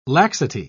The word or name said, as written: laxity